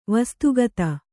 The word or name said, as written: ವಸ್ತುಗತ